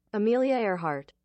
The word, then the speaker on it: Earhart: Amelia Earhart.